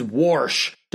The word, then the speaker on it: Warsh: So anyways, one of them is Warsh, Warsh. Think of the word wash if you were trying to say it like a hick.